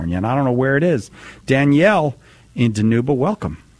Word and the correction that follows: Dinuba: It's actually pronounced Dinuba. – Dinuba? – It's okay. We're a really small town, just about 30 minutes outside of Fresno in the Central Valley. – Okay. Dinuba. Yeah. I apologize for not pronouncing it correctly.